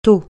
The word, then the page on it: taux: francais